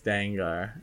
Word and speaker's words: Dengar: But I know starts with a D, rhymes with a ghost type Pokemon. – Oh man. – A Gen 1 ghost type Pokemon, third evolution trade only ghost type Pokemon. – It's Dengar.